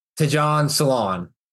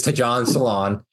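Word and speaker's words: Tidjane Salaün: So sixth overall, the Hornets draft to John Salon. And that is how you pronounce his name. I did look it up before we started here. It is to John Salon.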